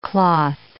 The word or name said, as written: cloth